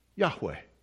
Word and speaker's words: Yahweh: We don't know how to say it. We think it's pronounced Yahweh.